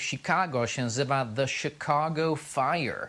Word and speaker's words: Chicago: Po angielsku się wymawia Chicago.